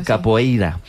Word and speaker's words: capoeira: Pues todos sabemos lo que es la copeira, ¿verdad? Capoeira. Qué mal pronuncio últimamente.